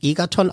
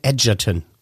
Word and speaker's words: Egerton: Der wird nicht Egerton ausgesprochen, sondern Edgerton.